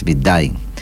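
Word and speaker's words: Schmidheiny: Y ahora esta persona es un suizo que se llama Stefan Smith-Heine, es un nombre dificilísimo de pronunciar. Uh-huh. Que ellos dicen es Bitdain.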